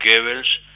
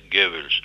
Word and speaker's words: Goebbels: o sea, Ronald Goebbels, pronunciado en inglés, que en realidad es Goebbels, alemán.